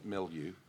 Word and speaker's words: milieu: The cultural milieu. – Yes. – Milieu? How do you pronounce that? – Yeah, milieu.